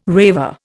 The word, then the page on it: raver: Indian